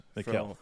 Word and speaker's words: Mikal: I almost said it wrong, but. – Malik? – Yeah, Mikal Bridges. – Mikal.